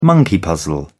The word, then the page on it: monkey puzzle: British English